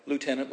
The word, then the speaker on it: Lieutenant: It's written by a lieutenant, we'll give them the American pronunciation, the Lieutenant General Hal Moore and a journalist named Joe Galway, and both of whom were in the thick of this battle.